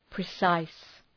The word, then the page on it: precise: prı’saıs